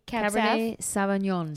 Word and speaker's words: Cabernet Sauvignon: 2016 Cabernet Sauvignon. Oh. How is that how you say it? – Okay. – Cabernet Sauvignon.